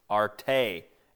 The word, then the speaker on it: arete: See, virtue here is translated from the Greek word arete, A-R-E-T, long E, arete.